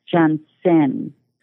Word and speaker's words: Johnsen: And I must say my last name is J-O-H-N-S-E-N, Johnson.